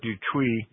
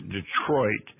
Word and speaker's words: Detroit: And then when we got up to Fort Detroit, actually, most Americans pronounce that differently. They pronounce it Detroit.